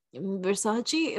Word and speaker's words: Versace: Donatella Versace, is that how you say it? Uh, yeah, it's fine. – Versace, I think.